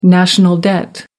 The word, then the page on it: national debt: American English